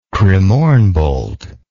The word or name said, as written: cremorne bolt